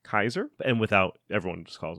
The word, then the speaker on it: Caesar: And since there is no soft C sound in Latin, Caesar is pronounced Kaiser.